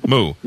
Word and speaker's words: Mu: How do you say this? M-U, is it Mu or Mu? – Mu. – Mu. – Mu, yes.